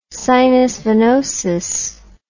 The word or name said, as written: sinus venosus